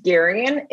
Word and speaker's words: Garyon: Well, my name's Garyon. It's often mispronounced, but it's pretty simple. It's like Marion, but with a G.